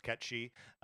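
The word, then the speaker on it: Cait Sith: There's a character in the game called Ketshi, spelled Kate Sith, but pronounced Ketshi, who has his limit break is a slot machine, and you can manipulate that to do an instant death on whatever enemy.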